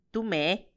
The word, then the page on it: tumee: Macuiltianguis